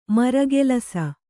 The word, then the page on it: ಮರಗೆಲಸ: maragelasa